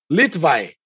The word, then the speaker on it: Litvay: Seja muito bem-vindo, Francisco Litvai. Falei certo? Falou certo.